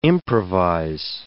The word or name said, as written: improvise